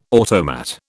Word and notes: automat: British English

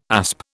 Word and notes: asp: British English

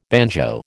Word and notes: banjo: American English

